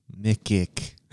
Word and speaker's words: Micic: M-I-C-I-C. How do you pronounce that name? – Mikic. – You got it.